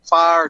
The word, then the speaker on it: Pharr: I'm at 801 North Jackson Street in Farr, Texas. That's spelled P-H-A-R-R.